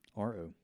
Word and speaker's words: Cagliostro: How do you say Cagliostro? – Cagliostro.